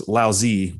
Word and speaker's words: Laozi: And he's referring to, I guess we would pronounce this Laozi, which was the author of, what is the name of the book? – Tao Te Ching.